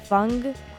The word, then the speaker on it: Fungk: So the last song that you listened to was Stellar Funk. Fungk. It's spelled F-U-N-G-K.